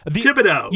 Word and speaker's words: Thibodaux: I would look at that, and I think reasonably, I would assume that that's pronounced Thibodaux. – You got it. – Very good. – Oh, that's right. You got it. Thibodaux.